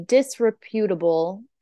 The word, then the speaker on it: disreputable: Oscar was known to police because he had a he was a disreputable foreigner. Did I write that word? I don't know what that means. – Disreputable? – Yes, there you go. I just said it wrong.